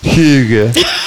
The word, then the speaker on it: hygge: For me, it's the sound of Jim pronouncing the Danish word hygge and shouting, I want the hamburger.